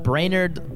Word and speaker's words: Brainard: My last name is spelled Brainard, brain, like the one in your head, A-R-D.